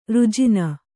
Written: ರಾಜಾಣ

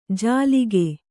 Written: ಜಲಿಕೆ

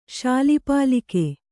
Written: ಶಾಲಿಪಾಲಿಕೆ

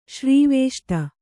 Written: ಶ್ರವಿಷ್ಠೆ

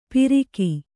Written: ಪಿರಿಕಿ

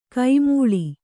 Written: ಕಮ್ಮಾಳ

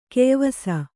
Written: ಕೆಯ್ವೀಸು

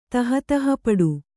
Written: ತಹತಹಪಡು

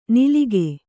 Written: ನೀಲಿಕೆ

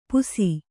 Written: ಪುಸಿ

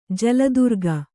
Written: ಜಲದುರ್ಗ

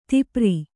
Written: ದಪ್ಪರಿ

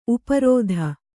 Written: ಉಪ್ಪಾರಿತ

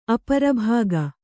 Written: ಅಪರಭಾಗ

